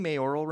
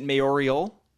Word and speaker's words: mayoral: Am I pronouncing mayoral right? – No. – Is it mayorial? – No, it's mayoral.